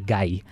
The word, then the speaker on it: Gay-Lussac: Tapos yung Gay-Lussac Law, okay, yung spelling niya G-A-Y. Pero ang pronunciation niya ay guy. – Guy. Okay. Hindi siya gay.